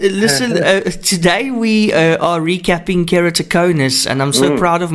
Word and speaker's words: keratoconus: Yeah, keratoconus. You did perfectly today. There's no problem with the pronunciation.